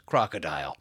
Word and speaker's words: Krokodil: This extremely addictive injectable opioid is called Krokodil, pronounced crocodile.